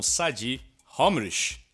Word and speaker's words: Sady Homrich: A gente está muito emocionado, muito impactado e felizes por começar o Labia Hopcast 2021 com Sadi Homrich. Falei certo, Sadi? – Aí, pô, se falasse Sandy ia dar pau, mas não. e ficou legal o Homrich também.